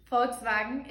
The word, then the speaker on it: Volkswagen: But it's pronounced Volkswagen in German.